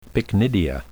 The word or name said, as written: pycnidia